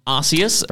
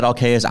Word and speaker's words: Arceus: And we had Pokemon Arceus. – Very true. – Is that how you pronounce it? – Honestly, Nintendo themselves have said Arceus and Arceus, but I'd prefer Arceus.